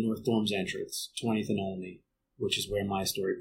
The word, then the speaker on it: Olney: I know it's supposed to be Olney, or if you want to get even more Philly, Olney. So for one, I wanted to acknowledge the half-joking slash contentious debate on the pronunciation.